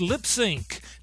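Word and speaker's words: Lipps Inc.: is pronounced Lips Inc.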